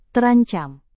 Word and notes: terancam: indonesian

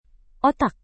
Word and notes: otak: Indonesian

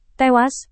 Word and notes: tewas: Indonesian

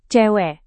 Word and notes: cewek: Indonesian

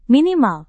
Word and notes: minimal: Indonesian

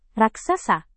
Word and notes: raksasa: indonesian

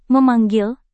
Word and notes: memanggil: Indonesian

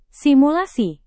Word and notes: simulasi: indonesian

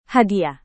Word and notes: hadiah: Indonesian

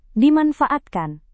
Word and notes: dimanfaatkan: indonesian

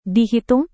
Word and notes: dihitung: Indonesian

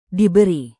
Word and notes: diberi: indonesian

